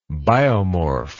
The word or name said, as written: biomorph